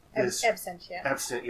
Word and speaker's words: Absentia: I love Abstentia. – I'm sorry, I'm blanking on the director. – Mike Flanagan. – And Abstentia is Absentia. – Yeah, I always say it wrong.